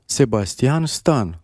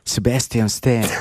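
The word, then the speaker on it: Sebastian Stan: Comment on prononce en roumain son nom? – Sébastien Stan. – Et en anglais? – Sébastien Stan.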